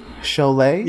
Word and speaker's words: Sholay: Sholay? – Yeah, that's right.